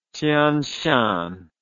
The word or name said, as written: Tyan Shan